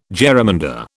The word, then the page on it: jerrymander: British English